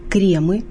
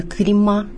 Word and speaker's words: кремы: Множественное число «кремы», не «крема», и «кремов», не «кремов». Формы «крем», «крема», «кремов» являются ошибочными.